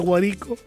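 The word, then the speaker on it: Guárico: De las siete parroquias que tiene el municipio de Trinidad Morán, una de las parroquias que lleva por nombre Guarico, se pronuncia Guarico, y estamos en sintonía.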